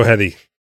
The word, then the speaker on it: Heti: and it's called A Diary in Alphabetical Order, A to C, by Sheila Hetty. Hetty? H-E-T-I. How do you say H-E-T-I? – Hetty? – Hetty? – I would go Hetty. – Sheila like it. Hetty.